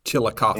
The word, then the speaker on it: Chillicothe: Then the next day, we actually went to the Kenworth factory, where we walked through the Kenworth factory in Chillicothe. I think it's how they pronounce it, Ohio. – Chillicothe. – And Chillicothe. Thank you for that.